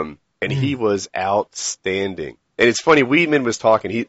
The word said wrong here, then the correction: Weidman: he's um I hope I'm not pronouncing it right. Uh, Weidman, Weidman.